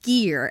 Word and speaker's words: skyr: So S-K-Y-R, pronounced skier, S-K-E-E-R, if you're kind of pronounce it.